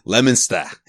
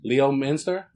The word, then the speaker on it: Leominster: Leominster. He's from Leominster, kid. – Leo Minster? – See, I didn't say it like that.